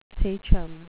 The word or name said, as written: sachem